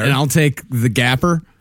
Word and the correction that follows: Gaper: It's the Gaper. Are you worried that I'm going to ruin the Gapper? It is Gaper. That is Gaper.